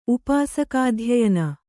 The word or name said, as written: ಉಪಾಸಕಾಧ್ಯಯನ